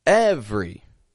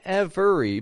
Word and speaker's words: every: Every. Same thing, spelled with three syllables, we only pronounce two. Not every, but every. Every other day.